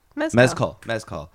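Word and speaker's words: Mescal: I'm like, was Paul Mezcal busy? That's not how you say his name, right? – I think it is. Mezcal.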